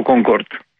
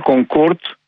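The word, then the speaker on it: Concorde: Είναι Concord και όχι Concord για ένα λόγο. Ήταν γαλλοβρετανικό αεροπλάνο, αλλά οι Γάλλοι που θέλουν να έχουν πάντα το προφέρει, το όνομα προφορά.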